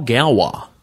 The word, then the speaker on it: Gallois: My good buddy Paul Galwa. Paul Galwa, that's spelled G-A-L-L-O-I-S. Paul Galwa, I guess it's French, may be the funniest son of a bitch I've ever met.